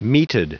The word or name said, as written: meated